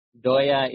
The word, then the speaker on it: Doya: Doya is the Bengali, Daya is the Sanskrit.